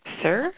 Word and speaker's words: Cyr: Well, Lynn, okay, how do you think you pronounce Lynn's last name? C-Y-R. Sir? – Sir, yeah.